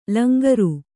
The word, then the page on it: ಲಂಗರು: langaru